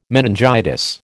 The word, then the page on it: meningitis: American English